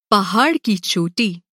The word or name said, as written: पहाड़